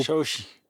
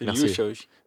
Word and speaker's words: Shaoqi: Shaoqi. Ah, merci. – Liu Shaoqi. – oui, excusez-moi, la prononciation de, la lettre Q se fait chi.